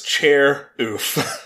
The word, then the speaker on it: Charoofe: It's spelled Charoofe, but it is Chair Oof.